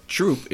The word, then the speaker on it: Throop: You can't tell it on the radio, but Troop is spelled T-H-R-O-O-P. It's spelled Throop. – But it's not Troop. – It's pronounced Troop because they can't say T-H. – Right. Well, it's 1-2-3.